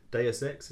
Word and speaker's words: Deus Ex: Uh, this week I'm playing uh, Deus Ex, is that how you pronounce it? – Yeah. Yes. Mankind Divided. Nice. Free game last month. It was. I love Deus Ex.